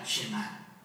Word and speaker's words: Shema: La primera palabra del Shema es oye o escucha, y en hebreo se pronuncia Shema.